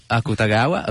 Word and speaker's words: Akutagawa: Akuga Tawa. I hope to have pronounced it well. Akutagawa, ok.